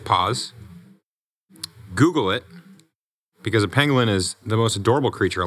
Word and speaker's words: pangolin: I want to say pangolin.